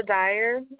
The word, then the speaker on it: Dyer: My name is Miranda Dyer, MIRANDA.